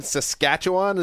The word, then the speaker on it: Saskatchewan: Kevin from Saskatoon, Saskatchewan. Is that being pronounced correctly? I'm a little off on my Canada stuff. – Perfect.